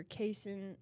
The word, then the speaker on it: casein: Protein, the protein in the milk is either casein, or it's casein and whey. Casein pronounced. Spelled C-A-S-E-I-N.